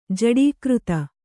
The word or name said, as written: ಜೋಡುಕಾರ್ತಿ